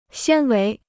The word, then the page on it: 纤维: xiān wéi